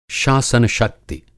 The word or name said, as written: शासन-शक्ति